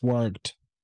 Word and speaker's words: worked: And very good pronunciation of the past, worked. Very, very well done.